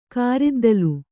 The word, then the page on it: ಕಾರಿದ್ದಲು: kāriddalu